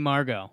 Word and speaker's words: Margot: The Rays traded Emilio Pagan to the Padres for center fielder Margot Mergot. Is that how you say his name? – Manny Margot.